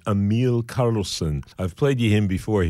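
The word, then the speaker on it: Emil Karlsen: His name is spelled E-M-I-L, first name, Emil, and then K-A-R-L-S-E-N, Emil Karlsson.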